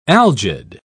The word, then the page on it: algid: British English